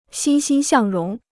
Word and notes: 欣欣向荣: xīn xīn xiàng róng

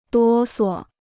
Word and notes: 哆嗦: duō suo

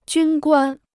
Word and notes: 军官: jūn guān

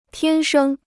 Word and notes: 天生: tiān shēng

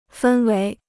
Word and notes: 氛围: fēn wéi

